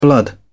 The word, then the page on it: blood: British English